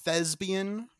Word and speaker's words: thespian: I believe it's pronounced thespian, rhymes with lesbian.